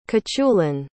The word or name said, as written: cuchulain